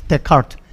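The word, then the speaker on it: Descartes: 데, 데카르트, 예, 루네 데카르트 어, 불어가 이게 뒤에 S는 발음을 안 합니다. 그래서 데카르트. 그래서 루네 데카르트라는 그냥 엄청난 그런 깊은 수학, 원래 수학자인데 철학자가, 야, 지금 이대로 가면 인류가 전체가 붕괴되고 다 죽겠다.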